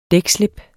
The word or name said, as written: dækslip